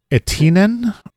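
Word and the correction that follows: Etiäinen: or something like that. It's Etiainen.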